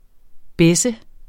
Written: besse

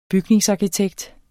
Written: bygningsarkitekt

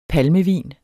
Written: palmevin

